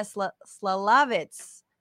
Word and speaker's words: Szalavitz: Please welcome Maia Szalavitzv. – Szalavitz. It's obvious. I was going to say the first Z is silent and the last Z is pronounced.